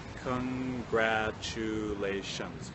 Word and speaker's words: congratulations: So if we count it off, we have congratulations. Okay, we have 5 syllables.